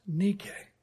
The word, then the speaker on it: Nike: Her name transliterated into English would be spelled D-I-K-E. We'd probably call her Dike. It was pronounced DK, which actually rhymes with Nike, which is actually pronounced Nike.